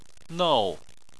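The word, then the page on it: nou4: tone 4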